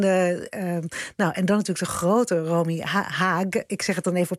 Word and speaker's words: Haag: Dus Romy Haag. Ja, dus ik ga ook zeggen in Nederland. Dus de Haag. Maar omdat zij vooral groot is geworden in Duitsland, hoor ik het meestal op zijn Duits uitgesproken.